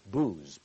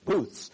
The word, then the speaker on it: Booths: We'll begin the Feast of Tabernacles, or Booths, or some have mispronounced it booze, but it's not booze, it's booths.